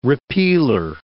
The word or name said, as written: repealer